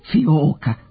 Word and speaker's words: fioka: Fioke nemaju slovo J, fascikle su fascikle, a plakate ne mogu biti plahate. Najpre o fiokama. Izgovor suglasnika J zavisi pre svega od susednih glasova u reči. Pravilno je da se glas J ne piše između samoglasnika I i O, osim kada je J deo osnove reči, niti kada su preuzeti gotovi modeli stranih reči. Prema tome, fioka koju smo preuzeli od mađarske reči fiok nema glas J, mada se njegovi elementi mogu čuti u izgovoru. Ni plakat nije naša reč.